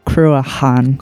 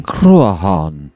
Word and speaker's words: Cruachan: Google just told us that the pronunciation of that band is Cruachan. – Cruachan.